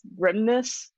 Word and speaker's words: Grimnes: It's Grimnes. So it's Grim and then Nes. A lot of people miss the N, and so they think it's Grimes.